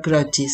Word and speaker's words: Croci: Eh, ella es una una Flor Croci, eh, Flor Croci, se escribe Croci pero se pronuncia Croci, lo dije mal yo,